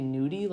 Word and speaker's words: Nudi: But it's spelled N-U-D-I, which I believe is supposed to be nudie, like the nudie brank.